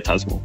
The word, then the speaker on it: Tusmore: Is that how you pronounce that? – Yeah, Tusmore.